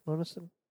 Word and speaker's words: Monessen: However, it was completely filmed in Dallas, Texas, except for a steel mill part that was filmed in Monessen, Pennsylvania. – Monessen. Oh, it is Monessen. It is Monessen.